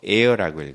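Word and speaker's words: heir: H-E-I-O H-E-I-O H-E-I-O 이것은 상속자란 뜻이에요. 나의 새끼 이런 뜻이죠. 그러면 이때 읽을 때는 H는 묵음으로 읽어요. Air라고 읽어요.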